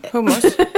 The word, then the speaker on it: hummus: Ja, hummus. H-U-M-M-us. H-U-M-M-us. Eh, det uttalas hummus, inte hummus.